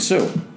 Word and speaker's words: Suh: I'd certainly also take a flyer on the guy whose last name rhymes with glue also, and Ndamukong Suh.